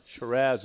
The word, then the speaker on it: Shiraz: Now, if you take Cabernet Sauvignon and add Shiraz or Syrah to it, Shiraz is the way they pronounce it in Australia, and you'll find that that is a very nice blend.